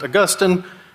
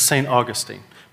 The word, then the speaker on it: Augustine: His name is actually pronounced Augustine, but as Americans do, we just lengthened a few vowels and shortened a few vowels, and he became, the town named after him is St. Augustine.